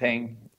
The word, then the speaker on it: Jinping: Jinping.